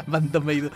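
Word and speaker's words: BAND-MAID: Domination de Bando Meido, como lo pronuncian en Japón. Bando Meido.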